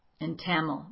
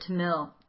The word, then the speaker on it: Tamil: But Tamil, I think is the proper way to say it, was his native language.